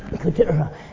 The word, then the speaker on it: Keturah: Keturah. That's how you say it in Hebrew.